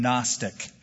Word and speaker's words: Gnostic: Have you ever heard that term, Gnostic? It's spelled G-N-O-S-T-I-C, Gnostic.